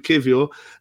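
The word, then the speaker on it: Kiwior: So I'll go with Kivior.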